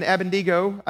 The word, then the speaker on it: Abednego: This is when Daniel, Shadrach, Meshach, and Abednego, I used to call him Abednego, but I don't think that's actually got all the syllables in it. We're going for Abednego.